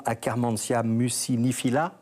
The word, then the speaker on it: Akkermansia muciniphila: alors je ne sais pas si je vais la prononcer correctement, Akkermansia muciniphila. – Parfait. – C'est comme ça.